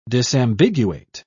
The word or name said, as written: disambiguate